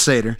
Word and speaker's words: Seder: so when I say Seder, that's only because other people have told me that's how you say it. – Yeah. – So don't get on to me, anyone. – It's Seder. It's Seder. It's all right. Yeah, you're good.